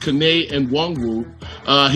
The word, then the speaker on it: Kene Nwangwu: Also, the Vikings' fourth-round running back, Kene Nwangwu, pronounced Kene Nwangwu, he returned a 98-yard kickoff against the Ravens as well, which is pretty impressive.